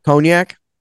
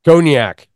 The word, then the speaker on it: Cognac: I can't say that word. How do you say that? – Cognac. – Cognac. Thank you.